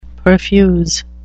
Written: perfuse

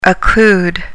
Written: occlude

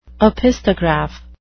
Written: opisthograph

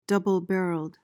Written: double-barreled

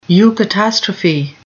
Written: eucatastrophe

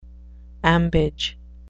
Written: ambage

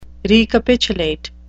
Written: recapitulate

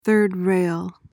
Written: third rail

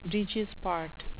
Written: breeches part

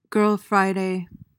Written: girl friday

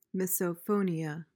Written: misophonia

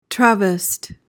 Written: travest

